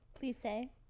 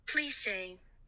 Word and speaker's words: plissé: Plissé. – So I said it right. – I got the pronunciation. Let's see if it goes again. – Plissé.